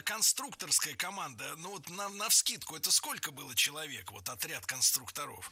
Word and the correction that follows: конструкторов: Конструктора, конструкторов, это неверно. В слове «конструктор» ударение должно сохраняться на втором слоге, на «у». Поэтому конструкторы, конструкторов. Кстати, если речь идет не о профессии, а о «лего», например, то ударение все равно падает на второй слог. Только конструкторы.